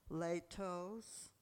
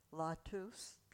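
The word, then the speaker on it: Lao Tzu: Latos, a Chinese mystic. Anybody familiar with that? L-A-O and T-Z-U. There are many books and periodicals out. Latos.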